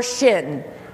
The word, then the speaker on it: shin: The picture for S, in Hebrew, it's pronounced sin or shin. And that, you know, sometimes it's shin, the sh sound, and sometimes it's the S sound.